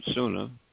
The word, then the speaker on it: sunnah: That's spelled H-A-D-I-T-H, hadith. The words hadith and sunnah, which is another word, sunnah, spelled S-U-N-N-A-H, are considered to be synonymous in the religious literature of al-Islam.